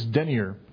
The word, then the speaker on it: denier: The name Gehazi, it means denier, D-E-N-I-E-R. It's pronounced denier, but it's someone who denies, a denier.